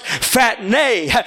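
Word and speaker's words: phatne (φάτνη): The Greek definition of the word manger is pronounced phatne.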